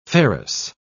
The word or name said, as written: ferrous